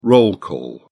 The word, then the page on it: roll call: British English